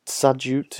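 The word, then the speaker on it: Tsadjout: And he ended up giving the opportunity, even though he didn't get on the pitch, to the young striker Frank Tsadjut, which I think is the correct pronunciation, Tsadjut, who's our starting Primavera striker at the moment, gave the opportunity to him on the bench instead.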